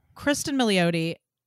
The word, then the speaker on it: Kristen Milioti: Kristen Milioti is so funny.